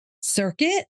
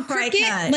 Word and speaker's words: Cricut: C-R-I-C-U-T. How do you pronounce it? – Circuit? – No, it looks like so cricket, like cricket. – Yeah, it's cricket. It looks like cricket. Like I always thought it was cricket. Most people do, but it's pronounced cricket.